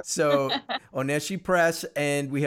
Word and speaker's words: Oneshi Press: And we should say it's Oneshi Press, right? I got the pronunciation correct again. – Perfect. – Yes, you did.